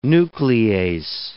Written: nuclease

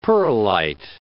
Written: pearlite

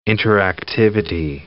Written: interactivity

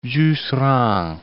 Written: Jusserand

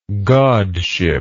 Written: godship

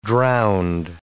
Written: drownd

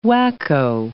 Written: wacko

